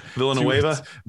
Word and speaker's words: Villanueva: Villanueva.